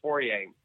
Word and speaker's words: Fourier: You start with Joseph Fourier, John Tyndall, I believe is how his surname is pronounced. – Tyndall. And Fourier is pronounced Fourier.